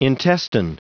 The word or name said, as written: intestine